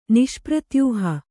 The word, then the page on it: ನಿಷ್ಪ್ರತ್ಯೂಹ: niṣpratyūha